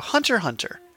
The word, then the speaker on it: Hunter x Hunter: So Hunter x Hunter, the X is silent.